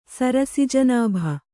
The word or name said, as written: ಸರಸಿಜನಾಭ